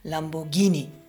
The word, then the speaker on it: Lamborghini: spricht man es nun Lamborghini oder Lamborghini aus? Man kennt das, äh, sind es es Gnocchi oder ist es Gnocchi? Wir sind also zu der Erkenntnis gekommen, es ist Lamborghini.